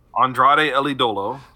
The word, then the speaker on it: Andrade El Idolo: Andrade El Idolo.